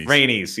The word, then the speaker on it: Rhaenys: How do you say her name? The queen Rhaenyra's? That was, what is it? – Rhaenys.